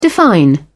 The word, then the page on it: define: British English